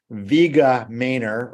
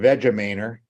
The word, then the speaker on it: Vegamainer: It is, you can pronounce it either way, Vega Mainer or Vega Mainer, VEGAMAINER.